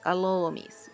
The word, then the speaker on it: Alholowmesse: Así se dice en inglés medio Em, qué es lo que significa Día de Todos los Santos, Alolomis, que es A-L-H-O-L-O-W-M-E-S-S-E.